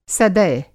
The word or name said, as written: सदय